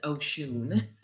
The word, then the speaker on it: Oshun: And I know it used to be Oshun, and as of, I want to say publicly today, it's Oshun, because we've been battling back and forth. – Yeah, great. – Okay, we will call it Oshun, because that is the traditional pronunciation of S-O-H-S-O-S-H-U-N. So you're going to, so Facebook, we're going to Saint Oshun, that is going to be S-T-O-S-H-U-N.